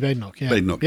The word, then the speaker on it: Badenoch: Kemi Badenoch.